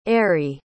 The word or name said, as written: arrie